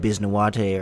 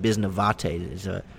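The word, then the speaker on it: Bisnowaty: And especially compared to some of the other tackles down here, Adam Bisnawate, or I think it's actually pronounced Bisnawate, the W is a V there for him, but the Pittsburgh kid, he not doing so well on the edge.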